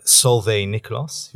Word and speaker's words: Solveig Nicklos: Let me introduce, from the Abu Dhabi School of Government, we have Solveig Nicklos, who's the dean.